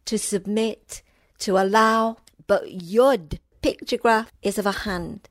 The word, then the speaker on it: Yod: This letter is Yud. It is spelled Y-O-D. Wow, what a letter. Positionally, in the Hebrew alphabet, is the 10th letter. Yud. I'll say it again. Yud.